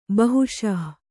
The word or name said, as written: ಬೇಹೈಸು